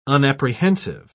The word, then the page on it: unapprehensive: British English